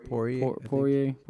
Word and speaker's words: Poirier: And I thought it was hilarious, the comment he made to, how do you pronounce this guy's name again? – Poirier,